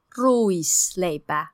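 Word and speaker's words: ruisleipä: 嗯，然后这个叫做 Lucy Leba。 – Louis Leba。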